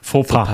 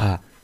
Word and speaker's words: Fauxpas: Wie wird das äh, ausgesprochen? Fauxpas. Fauxpas. – Fauxpas.